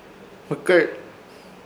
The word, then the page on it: Hmɨkẽr: Ishir Chamacoco